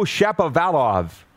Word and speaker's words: Shapovalov: Go Shapovalov. Did I say that right? – You did.